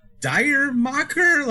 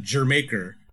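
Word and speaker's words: D'yer Mak'er: I'm like, Dire Mocker? Like, what the fuck? But it's supposed to be Jamaica spoken with an English accent, so that's supposed to be pronounced like Jamaica, basically.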